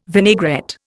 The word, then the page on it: vinaigrette: Indian